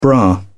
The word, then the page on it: bra: British English